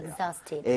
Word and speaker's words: exhausted: exhausted. – exhaust 약간 Z의 발음의 느낌으로 해서 발음 한번 해보도록 할게요.